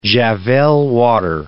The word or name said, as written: Javelle water